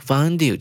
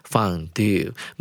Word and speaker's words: fondue: 注意到句尾的 fondue 这个字，这个字呢，其实英式念法会念成 fondue，美式念法念成。